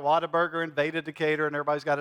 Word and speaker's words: Whataburger: I know it's spelled like what a burger, but it's pronounced Whataburger.